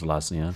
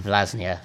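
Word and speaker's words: Vllaznia: So both the games against Vinylja, I believe we kept clean sheets. – They're actually pronounced Vlasnia. – Vlasnia, thank you.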